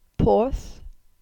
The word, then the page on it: Porth: Cornish